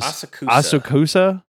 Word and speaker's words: Asakusa: Asakusa. – Yeah. Asakusa Thunder Gate exclusive All Might hoodie.